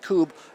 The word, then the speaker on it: Kubb: Again, we were up here last year with our friend Jane Matinair, and I know she had some folks on from Cube. We did as well. But, Pat, I know just tell us the quick overview for people, like, what the hell? It looks like Cub, K-U-B-B. Is pronounced Cube.